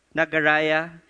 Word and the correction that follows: Niagara: Glenda, you're here. Okay. Niagara. Niagara.